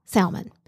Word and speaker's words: salmon: Well, there is an L, but I thought it was silent. I thought it was a silent L. Salmon.